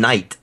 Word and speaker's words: Nike: I thought you were saying Nike. I guess that's. Yeah, I did not. How do you pronounce it? – NyQuil. What? – NyQuil. – It's Nike over here in the UK for the most part.